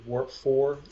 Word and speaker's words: Warp IV: Warp 4, it's spelled W-A-R-P-I-V, Roman numeral 4. Roman numeral 4 stands for the fourth dimension, time. So it's a play on words, time warp.